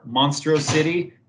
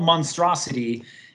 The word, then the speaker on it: Monstrosity: The last one I did was in St. Louis at this place, Monstrosity or Monstro City, if you say it wrong, because it looks like two words.